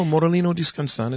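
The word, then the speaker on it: Morellino di Scansano: I want to try to say that Morellina Discansana. Did I say it right? Not with my Australian accent. – No, Morellino Discansana is fine.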